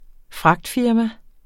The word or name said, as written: fragtfirma